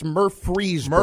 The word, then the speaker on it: Murfreesboro: Now, Middle Tennessee, it's located in, may not be pronouncing this right, sorry, Red Raiders, but Murfreesboro, Tennessee. – No, that's correct. – It's Murfreesboro.